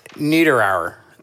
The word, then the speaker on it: Niederauer: N-I-E-D-E-R. Brandon. – I would say Niederauer. It's almost like Niederreiter, the Minnesota Wild player. – So Dickie and the Dinosaurs featuring Brandon Niederauer.